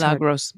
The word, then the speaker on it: Milagros: For Pod Pet Tax, here are pictures of our two cats, Smudge and Mila, short for Milagros. I hope I'm saying that right. Although it's Milagros.